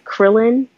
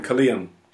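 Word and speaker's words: Kirlian: I don't want to butcher the name of the photography, but I think is it Krillin or Krillon photography? – Oh, Kalian. – Kalian.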